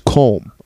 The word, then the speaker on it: Colm: His name is Colm, all right? That's how you say it. – C-O-L-M? – C-O-L-U-M. But Colm. – In Ireland, you don't pronounce the U, so it's Colm. But he moved to America, and now everyone calls him Colm.